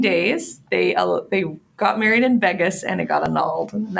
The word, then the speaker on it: Vegas: You're pronouncing it like it has egg in it, like Vegas. It's adorable. Oh, well, you're so Canadian. I love it. It's Vegas. – Vegas.